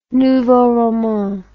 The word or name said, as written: nouveau roman